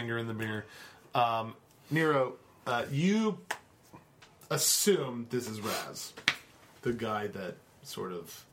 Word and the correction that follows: Rax: Rax. – Are you pronouncing the X like a Z? – Wait, I assume Rax is the governor's advisor?